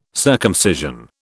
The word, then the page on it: circumcision: British English